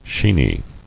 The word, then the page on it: Sheeny: shee·ny